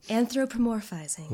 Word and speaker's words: Anthropomorphizing: Anthropomorphizing.